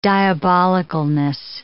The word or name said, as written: diabolicalness